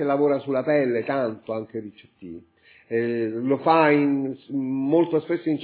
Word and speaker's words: Ricciotti: Sì, no, anzi lo conosco bene e non capivo l'accento. Rudy Ricciotti. Sì, Rudy Ricciotti, in realtà è Ricciotti, in realtà è abbastanza italiano.